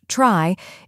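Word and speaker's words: trie: A try, retrieval tree, traditionally pronounced try,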